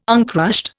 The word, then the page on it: uncrushed: Indian